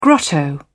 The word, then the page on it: grotto: British English